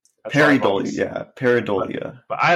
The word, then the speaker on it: pareidolia: I think I pronounce it with an N. I pronounce it parandolia. Uh, And I was like, oh, people are going to correct me. Uh, now people are. I'm going to say peridolia. – Peridolia? Well, I'm not going to f- fix it now because now I'm worried. I'm when I, had to spell it, I, there was no N in, in it.